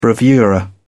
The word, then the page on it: bravura: British English